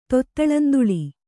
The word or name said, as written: ತೊತ್ತಳಂತುಳಿ